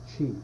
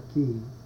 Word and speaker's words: qi: You can pronounce that qi, if you like, or qi. I learned G, so I use G.